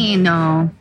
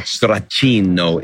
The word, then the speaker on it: stracchino: Stracchino. – And what is that? Can you explain to people out there in TV land what stracchino is? Did I say that right? – Yeah, it's a very delicious spreadable cheese.